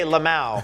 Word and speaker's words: LMAO: He responded with L-O-M-A-L-A-M-O-L-M-A-O. Oh, Jesus. L-M-A-O. Is that how you say it? – You actually say Lamau.